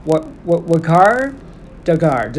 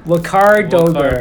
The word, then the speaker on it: Waqar Dogar: So help me to welcome my guest, Waqar. Dogar, did I say that right? – You got it.